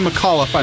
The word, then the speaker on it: McAuliffe: So, Girl School at the time was Kim McAuliffe on rhythm guitar, Kelly Johnson on lead, Enid Williams on bass. – McAuliffe, like the astronaut. – McAuliffe? Okay, Kim McAuliffe on rhythm guitar.